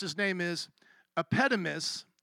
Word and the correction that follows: Epimenides: Epitomus, how would you say it? Epimenides. Okay, so Epimenides, who he's going to quote in just a moment, in him we live and move and have our being.